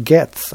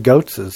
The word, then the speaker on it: Goetz: Goetz's candy company is pronounced Getz. I never knew that. – Yeah. – Yeah. Goetz's. I always said Goetz's because it's Goetz's. Goetz's. But it's Getz.